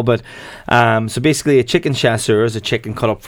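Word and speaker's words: chasseur: So you have chicken chassuer, am I pronouncing that correctly? – Yeah, so I figured I'd go for a wee French dish to kick it off, I suppose.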